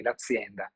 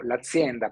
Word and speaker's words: azienda: Lo dico con la Z dura perché il boss Andrea Salvi ama pronunciarlo con la Z dura perché fa molto figo, quindi l'azienda, ma l'ho chiamato l'azienda proprio perché ha un'immagine aziendale.